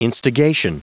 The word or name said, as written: instigation